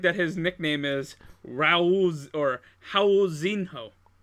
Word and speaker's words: Raul: His name is actually pronounced Howl.